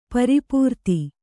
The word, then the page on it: ಪ್ರಪೂರಿತ: prapūrita